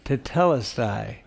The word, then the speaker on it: tetelestai: You know, "it is finished" is the word telestai. How do you say that? I had it in my mind. tetelestai.